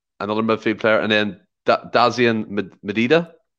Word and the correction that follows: Maeda: I think it's, I think it's Maeda.